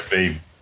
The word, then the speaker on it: Veeam: But my guest today is Veeam, or is it just Veeam? I'm not sure. How do you actually pronounce that company? It is just Veeam, yep. – Yeah, Veeam.